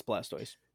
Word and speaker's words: Blastoise: It's got, what is it, Blastoise? Is that how you say it? – Yes, Blastoise.